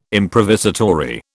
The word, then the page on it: improvisatory: British English